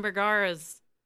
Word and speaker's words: Bergara: She's Ryan Bergara's wife, I think.